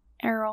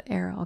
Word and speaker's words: Errol: I always forget how to say his owl's name. – Errol. – Errol. Okay. Like Aaron, but Errol.